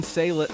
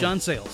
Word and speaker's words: Sayles: And we're doing 1996's Lone Star, directed by John Sayles. How do you say his last name? – John Sayles. – Sayles. Okay, I never knew how to pronounce that.